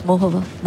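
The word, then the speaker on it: Muchová: Have you been listening to the Charmpire pronunciation of Mohova? Because it was Mohova.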